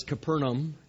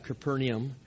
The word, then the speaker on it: Capernaum: and someone corrected me in the first service, Capernaum, not Capernaum, so I need to make sure I said it right. I said I was saying it with the Polish accent on it.